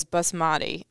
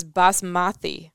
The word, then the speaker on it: basmati: Throughout the entire segment, you kept on pronouncing the word as basmati. The correct pronunciation is basmati.